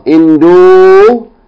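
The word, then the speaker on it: Indonesia (إندونيسيا): Okay, going over to the left column, we have the country Indonesia, and this word is pronounced Indonesia with two long vowels. Indonesia.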